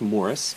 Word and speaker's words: Maurice: The film is called Morris, and it's spelled like Maurice, but it's actually pronounced Morris.